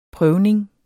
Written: prøvning

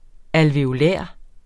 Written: ALVEOLARER

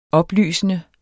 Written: oplysende

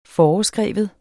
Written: foreskrive

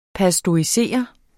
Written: pasteurisere

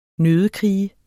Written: nøddekrige

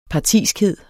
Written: partiskhed

